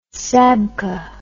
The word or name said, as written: sabkha